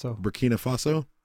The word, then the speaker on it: Burkina Faso: Burkina Faso? – You're the racist.